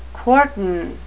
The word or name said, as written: quartan